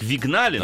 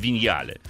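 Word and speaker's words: Vignale: Ну, она пишется как вигнали, но ага. мне сказали, что это я неправильно произношу. Она на самом деле виньяли.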